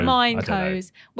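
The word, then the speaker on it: Mineko's: But we started off with Minico's Night Market. I think Minico's is how you say it. – Mineco's,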